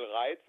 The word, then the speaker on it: Reitz: Reitz effect, it's a little joke with my name, because I'm Axel Reitz, it's my name, but it only means in Germany to trigger someone.